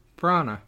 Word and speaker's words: Branagh: I'm probably pronouncing his last name wrong, also. Just throwing that out there. – Branagh. Kenneth Branagh.